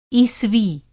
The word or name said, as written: ईसवी